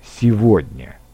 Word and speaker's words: сегодня: Меня спрашивают, почему в русском языке слово «сегодня» мы произносим «св» вместо «г» «сегодня». И вот мой ответ. Современное слово «сегодня» образовалось из двух слов «сего дня». «Сей», «сего» — это устаревшее форма современного слова «этот» этого. Оно сохранилось в слове «сегодня» и произносится по правилам произношения окончаний «ово», «ева», о котором мы говорили сегодня.